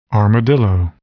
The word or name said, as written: armadillo